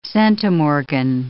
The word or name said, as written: centimorgan